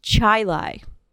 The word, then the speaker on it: Chili: She was from this town, upstate New York, that's spelled C-H-I-L-I, I guess it's a suburb of Rochester. So when you see C-H-I-L-I, you think Chile. Exactly. So I texted my friend who's from upstate New York, and I'm like, have you heard of this place? And is it pronounced Chile? And they're like, no, it's actually pronounced Chile.